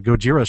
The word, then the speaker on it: Gojira: I was actually at the Gojira show, the Gojira show. I have to say it correctly.